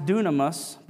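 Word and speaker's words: dunamis (δύναμις): This word power comes from the ancient Greek word pronounced dunamis, but it's spelled dynamis, and it's where we get the word dynamite.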